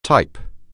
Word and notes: type: anglais

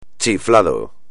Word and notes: chiflado: espagnol

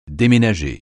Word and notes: déménager: français

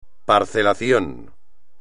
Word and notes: parcelación: espagnol

